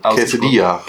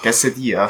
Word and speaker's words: Quesadilla: Habe ich das richtig ausgesprochen? – Quesadilla. – Quesadilla, sorry.